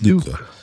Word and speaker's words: Nuka: I think it's Nuka. Because it's like, nuclear fallout, and it's just like N-U-K-A, cola.